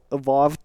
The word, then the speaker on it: Avowed: ktorý neviem vysloviť, to volá, že a Vote a no, normálne v O dvojité v e ED, a vo ved, hej, Avowed, whatever